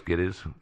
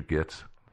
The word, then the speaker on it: Gittes: So Nicholson's name was Gittes, but this guy, he kept mispronouncing his name, Mr. Gitz.